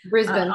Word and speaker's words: Brisbane: Brisbane. – Australia. Yes, Brisbane.